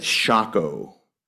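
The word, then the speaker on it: Socoh: I've been mispronouncing that. It's Shaco.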